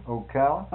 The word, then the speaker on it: Ocala: That was the nicest fellow at the Texaco station just before it came out of Ocala. I guess, am I pronouncing that right? – Sure.